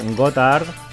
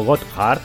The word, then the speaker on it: Gotthard: Así de bien suena lo nuevo de los suizos Gotthard o Gotthard, que esto cada uno lo pronuncia como bien le viene.